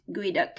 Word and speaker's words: Geoduck: And then uh, another author, I'm gonna mess up their name, but it's spelled Geoduck, but I'm pretty sure it's pronounced Guiduck because there's some sort of like real life creature pronounced that way.